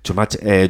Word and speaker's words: Schumacher: ¿Cómo se dice? Perdona, que tú sabes decirlo bien. – Schumacher. Joel Schumacher.